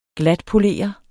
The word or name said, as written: glatpolere